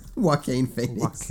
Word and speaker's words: Joaquin Phoenix: I can never say his name. Joaquin. – Joaquin Phoenix. – Joaquin. – There you go. Joaquin. I always forget what it is.